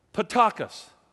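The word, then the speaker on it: ptochos: It's called P-T-O-C-H-O-S. P-T-O-C-H-O-S. And you pronounce it this way. Potakos. Almost sounds like potako, doesn't it?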